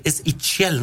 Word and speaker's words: Itzel: Itzel Sánchez. – Itzel. I-T-Z-E-L. Itzel. Que la pronunciación me decía, es Itzel, ¿no? – No, Itzel.